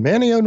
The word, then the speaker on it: Manteo: But one summer, I was 4 years old and had been watching Superman at home, and I'm visiting my cousin in Manteo, North Carolina. That's M-A-N-T-E-O. They don't pronounce the T. Manteo, North Carolina. And I said, hey, it's time for Superman.